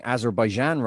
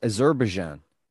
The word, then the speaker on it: Azerbaijan: I met someone last night that told me I was pronouncing Azerbaijan wrong. She's from Azerbaijan. It's Azerbaijan. Amazing.